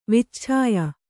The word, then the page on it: ವಿಚ್ಛಾಯ: kannada